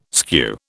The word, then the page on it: skew: British English